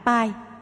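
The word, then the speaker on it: phaṭ: Pháp tu Pai hay giáo lý Pai, chữ Tây Tạng Phát, phát âm là Pai, thường có trong chú thuật mật giáo.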